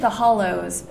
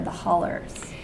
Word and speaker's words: The Hollows: Hollers is just the way people pronounce Hollows, right? So I'm calling it The Hollows because it rhymes with The Widows, and that's the official name of the town in my book. And also in real life, it was the Moonville Tunnel. And if you drive, as you know, if you drive around down there, there are all these roads named like Steinhardt Hollow. And I mean, they would pronounce it holler, but the word's hollow. So it's The Hollows.